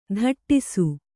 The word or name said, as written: ಧಟ್ಟಿಸು